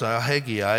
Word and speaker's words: Haggai: And that's the way you pronounce it as far as Haggai.